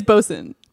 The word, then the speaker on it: boatswain: It's spelled weird, but it's pronounced bosun.